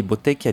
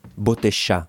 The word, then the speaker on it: Bottecchia: per strada i francesi lo acclamano e pronunciano il suo nome alla loro maniera, con la doppia T che scompare e quel CHI finale che diventa SH, e ovviamente l'accento sulla ultima A. Quindi Bottecchia diventa per i francesi Bottecha, lo chiamano così, e quel nome Bottecha, nato come una storpiatura, diventerà più suo di quanto chiunque potesse all'epoca immaginare.